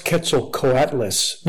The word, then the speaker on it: Quetzalcoatlus: You mean these are the Quetzalcoatls? – It's pronounced Quetzalcoatlus, not Quetzalcoatlus.